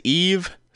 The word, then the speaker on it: Yves: Her name is Eve Smith. But it's spelled Y-V-E-S.